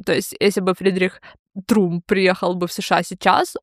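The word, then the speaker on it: Trump: Прикол в том, что, короче, контекст того времени в США, когда Фридрих Трамп приехал в США, Фридрих Трумп, точнее, так надо его называть. – Да. – Потому что Трамп, да, А, это будет английское, да, уже произношение этой фамилии, а на немецком это будет Трумп.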